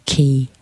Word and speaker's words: Qi: is it spout or pronounced the same way? Qi, qi, the Chinese in Chinese medicine.